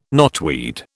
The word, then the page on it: knotweed: British English